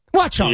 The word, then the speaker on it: Watchung: Watchung. – Watchung.